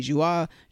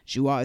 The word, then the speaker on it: Joie: You guys can find me at Demi Joua. Joua is spelled J-O-I-E.